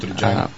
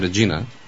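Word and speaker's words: Regina: От Реджайна. – От Реджайна, както ние наричаме. Пък Шкумбата правилно ни поправи, че е Реджина. Или как беше произношението? – На български се изписва Регина.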